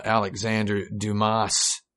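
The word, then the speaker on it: Alexandre Dumas: Uh, so I guess when I was a kid, I really liked, uh, Alexander Dumas. I pronounced that in English.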